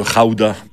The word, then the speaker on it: gouda: Le pays d'où vient le fromage, le gouda ou le rauda, parce qu'ils prononcent les G comme des R dans ce pays, c'est les Pays-Bas.